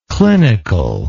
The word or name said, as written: clinicalness